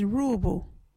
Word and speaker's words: ruubu: Our local Wadjuri word is roobu. What is a roobu? Roobu is a word for rope. You can use roobu in this sentence. Get that roobu. Roobu is spelt R-U-U-B-U. Can you say roobu?